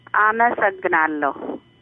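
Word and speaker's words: Amasagnalo (አመሰግናለሁ): And how do you say thank you in Ethiopian? – Amasagnalo. – Amasangrado. Oh, thank you.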